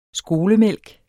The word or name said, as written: skolemælk